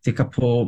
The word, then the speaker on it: Tekapo: And then there's this adventure playground at Lake Tekapo, T-E-K-A-P-O. – Tekapo, yep. – Tekapo, is it? Is that how it's pronounced? – That's right.